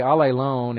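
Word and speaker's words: allelon (ἀλλήλων): That little, by the way, allelon is A-L-L. If you don't read the Greek letters there, that's A-L-L, and that's a long E, L. O N, that's a long O. So A-L-L-E-L-O-N is what that turns into, with a long E and a long O.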